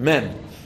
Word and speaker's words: Mem: How do you pronounce the letter Mem? Mem. Mem means you open your mouth and you close your mouth. Meh, mm. So, meh, mm.